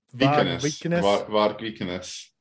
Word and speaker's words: Varg Vikernes: Varg Vikernes.